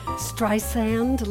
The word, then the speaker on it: Streisand: Barbara Streisand. – Streisand? Like sand on the beach, soft S.